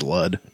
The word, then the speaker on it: Lud: It might be Lude, but I think it is Ludd. It's spelled L-U-D.